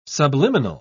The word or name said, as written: subliminal